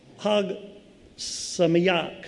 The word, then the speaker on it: Chag Sameach: And it doesn't quite pronounce, this is translated, but it doesn't quite pronounce how it looks. It's Chag Sameach.